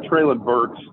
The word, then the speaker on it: Treylon Burks: Well, the thing about Treylon Burks, and you said it right, Jack, I don't care that he ran a 4.5 240 at the combine.